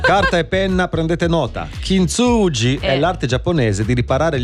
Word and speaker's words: Kintsugi: No, Kintsugi.